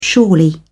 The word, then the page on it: surely: British English